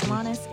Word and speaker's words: Alanis: I am your host, Alanis, and as always, you can find me on Instagram at Alanisella.